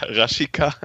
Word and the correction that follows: Rashica: der gute alte Raschika. Falsch gesagt. Ist ja anders. Raschica.